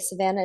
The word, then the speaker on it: Savanah: And Savannah is spelled Savanah.